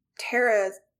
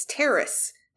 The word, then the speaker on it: Terrace: I thought that for the longest time, too, because when people say Tara, they say it like Tara's house, and I'm like, it's Terrace, like a terrace.